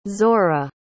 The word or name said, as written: zora